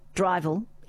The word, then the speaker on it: drivel: But Drivel in Scottish is pronounced drivel, means spoken nonsense.